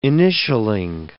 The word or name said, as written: initialling